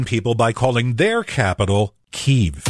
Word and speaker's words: Kyiv: So I went looking for the answer, and it turns out in Russian, the city's name is Kiev. In Ukrainian, it's always been pronounced Kyiv, and the rule is Ukrainians call their city Kyiv, so reporters call the city Kyiv.